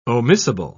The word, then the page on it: omissible: British English